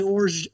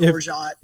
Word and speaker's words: orgeat: So I don't say orge to make it French. I say orgeat.